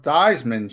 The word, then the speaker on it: Theismann: Joe Theismann changed it to rhyme with Heisman, but he didn't win the Heisman.